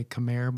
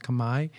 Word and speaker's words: Khmer: We say Khmer, but it's pronounced Khmer.